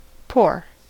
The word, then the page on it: pour: American English